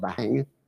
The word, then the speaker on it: bhang: The Hindu religion had used it as part of their festivities, just in the creation of that drink called bhang. Am I pronouncing that correctly? – Bhang. Bhang. Bong? Yeah, bong was kind of like a long A sound. – Don't pronounce the H, so why do they even have it?